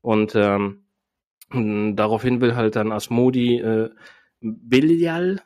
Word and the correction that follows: Belial: ist das richtig ausgesprochen, Ivo? Du wirst das gleich nochmal eingehen, was ja, was dieser Name zu bedeuten hat, aber sage ich das richtig? – Ja, also eigentlich wird er Belial ausgesprochen, aber auch hier im Verlauf der Folge wird es mehrfach äh, unterschiedlich ausgesprochen. Aber eigentlich ja. korrekt ist Belial.